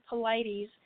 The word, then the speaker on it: Paulides: They did uh, Paul, excuse me, I'm saying that wrong, David Paulides.